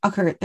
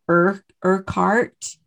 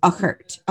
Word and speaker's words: Urquhart: And I'm saying it the way that it should be pronounced, but actually, if you were to read it, it almost looks like it's Urquhart. – Right. – But it's Uckert. Uckert is the way that I would say it.